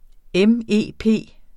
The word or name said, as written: MEP